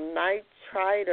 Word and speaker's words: nitrite: And then I learned that beets have in it something called nitrite, or I may be pronouncing it wrong. No, you pronounce it. Actually, yours is more correct than mine because I have an accent, you know.